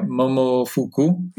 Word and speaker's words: Momofuku: So tell me about when you were at, I would hope I pronounce this right, Momofuku.